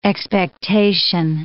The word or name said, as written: expectation